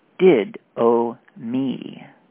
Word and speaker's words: didomi: Strong's number 1325, D-I-D-O-M-I, pronounced did-o-me, with the emphasis on did.